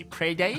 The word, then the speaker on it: Prada: Prada.